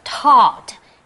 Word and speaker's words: taught: 所以这个字很重要 teach, 教教什么什么或者是教导那么它的动词呢的过去式跟过去分词会变成 taught T-A-U-G-H-T taught 那么 G-H 是不发音的三态 teach,